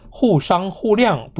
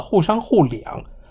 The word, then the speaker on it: 互谅: 习近平在读这一段的时候，一开始就把互商互谅读成了互商互两，四声读成了三声，接下去抬了一下头，就把构建命运与共的全球伙伴关系不小心读成了构建人类与共的全球伙伴关系。